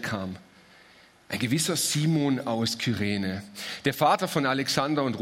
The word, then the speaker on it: Kyrene: Das ist das, was wir zunächst mal von Simon von Kyrene, so wird das ausgesprochen, dieses Z, was wir über ihn erfahren.